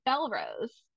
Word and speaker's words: Bellerose: I had Alexa help with my reread, and then it said Belarose. That's what it was. And I was like, wait a minute, the whole time I was saying it wrong, which I kind of love.